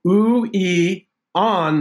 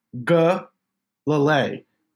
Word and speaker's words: Uiagalelei: It is Uiagalelei. That's your emphasis. Uiagalelei. So I've screwed this up a few times. I think I've got it now. Uiagalelei.